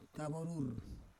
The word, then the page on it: kavorur: Roon